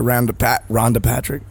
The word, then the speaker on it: Rhonda Patrick: And we're back talking to Dr. Rhonda Patrick.